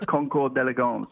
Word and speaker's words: Concours d'Elegance: Sydney Harbour Concours d'Elegance, that's how you say it.